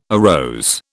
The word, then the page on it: arose: British English